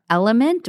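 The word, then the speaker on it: LMNT: Three other popular options are Element, which is spelled L-M-N-T, L-M-N-T. It looks like an abbreviation, but you say Element.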